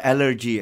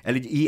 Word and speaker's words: elegy: Not allergy, as in like you're allergic to... Elegy, E-L-E-G-Y. Uh-huh. Am I pronouncing it? Elegy.